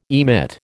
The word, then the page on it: emit: American English